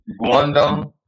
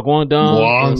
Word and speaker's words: Guangdong: Guangdong. – Oh, Guangdong.